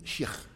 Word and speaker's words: Sheikh: Now, Sheikh, pronounced in the Orthodox manner, is Sheikh, which is Arabic, which means I was born into a Muslim family in West Africa, Sierra Leone, many, many moons ago, precisely when, 1960.